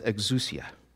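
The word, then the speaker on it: exousia (ἐξουσία): The Greek word here is pronounced exousia.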